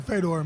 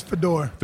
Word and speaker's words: Fedor: Well, I pronounce it Fedor, but technically I believe in Russia it's Fedor.